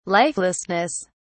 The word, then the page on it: lifelessness: British English